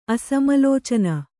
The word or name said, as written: ಅಸಮಲೋಚನ